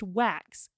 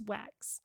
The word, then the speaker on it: Wachs: And it was said that it's pronounced Wax in her IMDb because it's spelled W-A-C-H-S. – Oh. – But it's Wax.